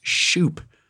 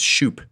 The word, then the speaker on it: Shupe: Not to belabor my name woes, but my maiden name is Shoop. Shoop, S-H-U-P-E, pronounced Shoop.